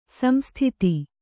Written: ಸಂಸ್ತುತೆ

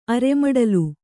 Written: ಅರೆಮಡಲು

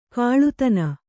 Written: ಕೀಳುದನಿ

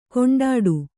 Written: ಕುಂಟಾಟ

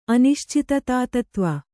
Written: ಅನಿಶ್ಚಿತತಾತತ್ವ

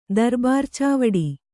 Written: ದರ್ಬಾರ್‍ಚಾವಡಿ

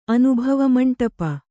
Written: ಅನುಭವಮಂಟಪ

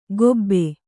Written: ಕೊಬೆ

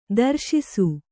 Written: ದರ್ಶಿಸು

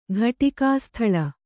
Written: ಘಟಿಕಾಸ್ಥಳ

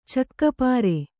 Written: ಚಕ್ಕಪಾರೆ

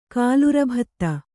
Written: ಕಾಲುರಭತ್ತ

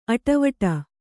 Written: ಅಡಿವಿಡಿ

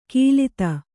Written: ಗಿಲಿತ

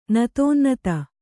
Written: ನತೋನ್ನತ

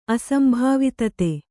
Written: ಅಸಂಭಾವಿತತೆ

